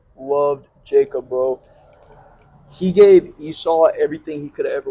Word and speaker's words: Esau: Yeah, Esau.